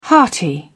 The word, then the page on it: hearty: British English